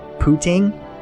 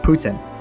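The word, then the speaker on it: poutine: I've heard the, you know, people in Canada, in Quebec, pronounce this word pooting. – Okay. – Or Putin. Putin. I've also heard it pooting. – Okay. – Whatever that is, it's topped with fries, pooting gravy or Putin gravy. Heard it pronounced both ways, according to the internet.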